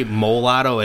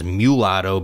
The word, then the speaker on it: mulatto: You say mulatto. It's actually, the correct pronunciation is mulatto because of, you know, the origins that I just explained to you.